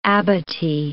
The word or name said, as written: abatis